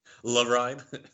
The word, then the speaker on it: Le Ride: Le Ride. – Le Ride, yeah. – French pronunciation.